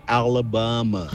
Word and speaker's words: Alabama: Hace rato se escuché, pusieron una de las canciones favoritas de Sweet Home Alabama, y tenés totalmente correcto, no se pronuncia Alabama, se dice Alabama.